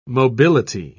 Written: mobility

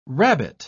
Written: rabbet

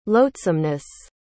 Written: loathsomeness

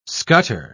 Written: scutter

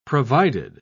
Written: provided

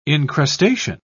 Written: incrustation

